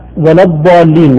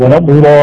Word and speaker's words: ولا الضالين: ولا ندخل المشاهدين فيها، لكن النطق صحيح يعني احيانا يبالغ في المد. – نعم. – ويبالغ ايضا في الركون على اللام. – اي نعم. – ولضالي، هذا خطأ. – خطأ خطأ.